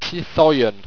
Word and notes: tthoien: owstwecian

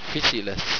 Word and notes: ffisiless: Owstwecian

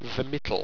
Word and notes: vmitl: owstwecian

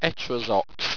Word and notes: etrezox: Owstwecian

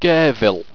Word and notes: ghyrrvl: Owstwecian

